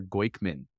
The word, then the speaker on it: Goykhman: My birth name is Ellie Mayer Goykman, G-O-Y-K-H-M-A-N.